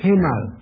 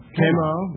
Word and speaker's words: Kemal: How do you suppose we pronounce that, Mr. Kemal. – Kemal. Yeah.